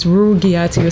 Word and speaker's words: Rugiatu: It's Rugiati. It's hard G. – It's a hard G. Here I am thinking that I'm being fancy, you know. – Rugiatu. – Yeah, so it's Rugiatu Conte.